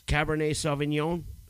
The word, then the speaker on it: Cabernet Sauvignon: Cabernet Sauvignon? – Yeah, that sounds about right.